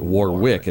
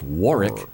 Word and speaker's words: Warwick: Scientists want to know what's funny, and according to researchers at England's Warwick University, that's how they pronounce it, right, Greg? It's not Warwick, it's Warwick University, like they do in the East Coast.